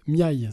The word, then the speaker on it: Miaille: ça sera La Traversée de Florence Miaille, c'est comme ça qu'on prononce ? – Oui.